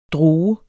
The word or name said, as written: droge